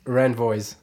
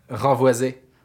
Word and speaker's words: Renvoise: And how do you usually have Americans pronounce that? – Renvoise. – That's so bad though. Sorry, yeah, say it to me the right way one more time. – Renvoisé.